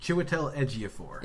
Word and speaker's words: Chiwetel Ejiofor: Fantastic performances from, I might butcher this, but it's Chiwetel Ejiofor? – Chiwetel Ejiofor.